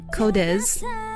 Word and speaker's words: QODES: Sorry, Codes. That's how you pronounce it. It's spelled Q-O-D-E-S.